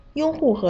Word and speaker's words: 拥护: 拥护和用户的区别在于第一个字，拥护的“ 拥” 是一声，用户的“ 用” 是四声。